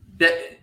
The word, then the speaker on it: Pache: Pache? How do you say his last name? – Yeah, Christian. Pache.